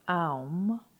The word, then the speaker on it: aum: Now, we are going to intone the vowel sound aum, spelled A-U-M, 7 times. The intonation of aum produces a simultaneous effect on the 3 planes of our being and harmonizes the physical, psychic, and spiritual bodies. Aum is normally intoned on D natural below middle C.